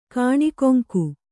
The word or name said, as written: ಕನಕಾಂಗಿ